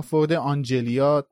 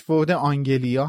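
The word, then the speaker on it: Ford Anglia: البته اینم باید اشاره کنیم که به اشتباه فورد آنجلیا ترجمه شده که نمیشه از نویسنده، یعنی نمیشه از مترجم خورده گرفت، چرا که خیلی نزدیک به آنجلیاست، یعنی ممکنه که این اشتباه پیش بیادش، ولی تلفظ صحیحش فورد آنگلیا هست که باز هم همیشه سوال بود چرا فورد آنگلیا.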